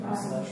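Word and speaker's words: La Salette: I had taken Christian to, I never say it right, the Lasalette. – That's right, Lasalette Shrine. – Well, that's the first time I've said it right.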